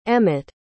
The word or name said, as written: emett